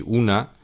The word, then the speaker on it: un: Vamos a ver estos determinantes que en español son un y una. En francés se pronuncian de una manera bastante diferente. Tenemos el masculino que se pronuncia un y el femenino que se pronuncia une. Repito, un, une. Son bastante diferentes.